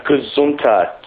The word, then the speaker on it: Gazunta: Gazunta, G-A-Z-U-N-T-A. Or for the American pronunciation, G-A-Z-U-N-T-A.